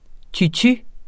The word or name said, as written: tutu